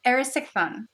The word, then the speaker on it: Aristophon: So the Greek king, how do you say his name? – Aristophon.